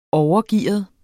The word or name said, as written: overgearet